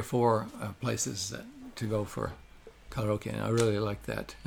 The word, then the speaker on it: karaoke: Karaoke is what karaoke. Americans say, but karaoke is what they say in Japan.